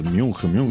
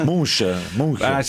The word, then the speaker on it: Munch: a come si pronuncia poi? Munch. – Munch. – Genova, Palazzo Ducati. Munch. – Sì, alla tedesca è più Munch.